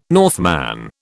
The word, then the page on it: Northman: British English